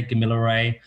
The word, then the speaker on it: Kamilaroi: Some people say Gomeroi, some people say Kamilaroi. There's lots of pronunciations and spellings.